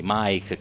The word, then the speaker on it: Make: Mike è nata in Germania, quindi porta questo nome tedesco, si scrive Make e si legge Mike Clarelli, cantante, autrice, direttrice musicale del coro di donne migranti Le Chemin de Femmes.